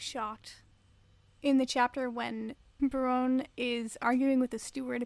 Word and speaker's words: Berrone: And apparently, it is Berrone, which is a lot better than Baroni to rhyme with macaroni.